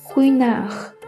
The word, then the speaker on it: Ruinart: 今日教大家惠纳香槟的法语发音，惠纳赫，惠纳赫，惠纳赫，你学会了吗？